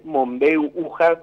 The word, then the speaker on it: Mombeu Uja: Sí, no te corregí de entrada, pero porque sabemos, sí. es Mombeu Uja, como se pronuncia, sí.